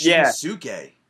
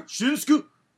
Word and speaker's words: Shinsuke: It's Shinsuke. Where are you seeing Shinsuke? – That's how you pronounce it. Like Shinsuke? – You don't pronounce the U.